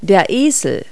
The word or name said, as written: donkey